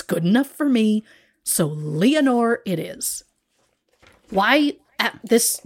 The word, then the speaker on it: Leonore: While some simply call her Lenore, I'm going to pronounce it Leonore, as it has been said in the movie Hollywoodland, and by Lois Lane herself, Phyllis Coates.